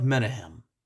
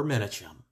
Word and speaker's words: Menahem: Now, one example may be, we have a rebel leader by the name of Menahem, or Menachem, it's pronounced both ways, who broke into King Herod's armory at Masada.